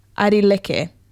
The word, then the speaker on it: Airileke: His name is Airi Kele. Oh, no, sorry. I butchered that. Airi Leke, I think is how you say that. A-I-R-I-L-E-K-E is how you spell it.